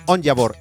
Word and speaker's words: Onyeabor: Eh, y ya se van a dar cuenta porque estamos hablando de William Oyabor. A ver, no, ¿cómo se pronuncia bien? Onyabor, eso.